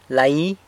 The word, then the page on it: lai: sanlucasquiavini